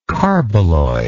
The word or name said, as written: carboloy